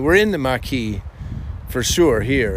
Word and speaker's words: Maquis: Maquis, like marquee, but without the R. Yeah. – Maquis.